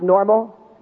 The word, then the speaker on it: NORML: Their name is N-O-R-M-L. Their name is pronounced normal, and they are lobbying for the legalization of marijuana in the United States.